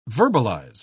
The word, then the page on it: verbalise: British English